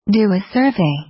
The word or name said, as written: do a survey